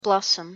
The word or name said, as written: blossom